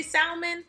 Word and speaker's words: salmon: Everybody makes fun of the way I say salmon. – Salmon. – Because salmon. – I pronounce the L, but I don't care.